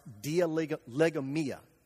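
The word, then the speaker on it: dialegomai: There's a Greek word that Luke uses there, which is a Greek word that's pronounced dialegomia. Dialegomia.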